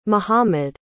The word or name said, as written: mohammed